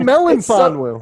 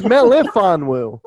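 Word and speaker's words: Melifonwu: See, you just did it again. You just called him Melinfonwu. It's Melinfonwu. There's no N.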